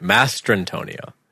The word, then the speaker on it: Mastrantonio: I like Mary Mastri. How do you say it? I always fuck it up. Mastren. Fuck, man. I can't say her name for the life of me. Mary Elizabeth Mastrantonio. Mastrantonio. – Yeah, you're right. Mastrantonio.